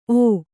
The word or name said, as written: ಓ